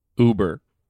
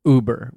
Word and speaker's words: Uber: And then we're into his over. – Confess Oprah, why he didn't. – Over. How do you say this word? – Uber. – Uber.